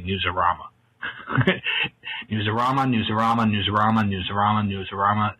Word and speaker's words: Newsarama: It is Newsarama. It's Arama anyway. No one says Rama. It makes it something else.